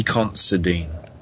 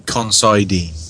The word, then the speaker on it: Considine: Paddy Considine. Or Considine, depending how you want to pronounce it.